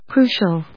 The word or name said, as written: crucial